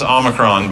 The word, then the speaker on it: Omicron: And it is pronounced Omicron, by the way. Uh, I study Greek. 15th letter of the Greek alphabet is Omicron, not Omicron.